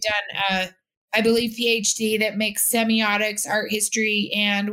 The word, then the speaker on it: semiotics: And is it semiotics? Do I say that right? – You did. Perfect.